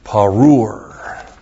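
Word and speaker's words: Parur (פָּרוּר): It's described by parur.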